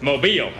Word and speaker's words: Mobile: It's Mobile, Alabama. That's the way it's pronounced. It's Mobile. They're very adamant about that. It's Mobile. It's not Mobile.